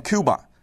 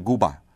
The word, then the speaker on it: Cuba: 这个呢，那一年我们电台组织听众去古巴的时候，我也在节目上大力的推荐过这个纪录片，叫做 Cuba Libre。那当时我们在宣传的时候就宣传啊，说去这个古巴要看这个 Cuba Libre，还有听众打电话说，哦，你们发音发错了，古巴这个国家的英文念 Cuba，没错，这位听众提的一点都没错，但是在西班牙语里面，古巴 Cuba 这个国家的发音是 Cuba，那个时候中国大陆抗议美国的时候是叫什么 Cuba C Yankee No 啊，就是古巴 C 就是西班牙语的 Yes。